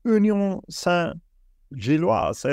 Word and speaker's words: Union Saint-Gilloise: 5 mål hittills för Union Saint-Gilloise. Är det så man uttalar det? – Ja, bra ut.